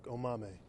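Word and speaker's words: Omameh: And Patrick, the fans have asked, did I get the last name right, and can you pronounce it for the fans out there? – You got my last name right. It is Patrick Omame.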